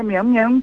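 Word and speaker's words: miam miam: Aliás, eu nem sei se eu pronunciei certo. É Mian Mian mesmo? – É, Mian Mian para você.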